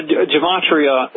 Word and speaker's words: Gematria: Can you tell us a little bit about what Gemantia is? Is that, am I pronouncing that right? The number system that the occult frequently uses? – Yeah, Gematria, they assign a number value to a letter and then add that up.